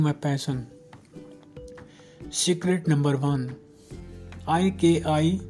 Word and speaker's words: ikigai: In Japan, millions of people have ikigai, pronounced as ik-i-gai. So that's how it is pronounced, ik-i-gai, a reason to jump out of bed each morning.